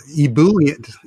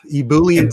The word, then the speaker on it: ebullient: There's a, in fact, Ebullient, is that the name of it? Did I say that right? – You did not. Ebullient. Ebullient. – Ebullient. Okay, like E-B-U-L-L-I-E-N-T.